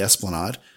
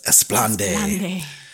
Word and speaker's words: Esplanade: Starting on October 1st, there will be additional photo opportunities near the Esplanade, or as Karin says, Esplande. Which, this is a funny story. I've known multiple people to think that the Esplanade is pronounced Esplande. So if you're one of those people, you're wrong.